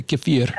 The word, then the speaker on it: kefir: and there's a guy there that gets it and then he makes kefir out of it or oh. kefir mm-hmm. as it's pronounced as the russians and, say kefir uh-huh.